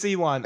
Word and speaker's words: Ewan: No, Ewan. Ewan. You're right. It's Ewan.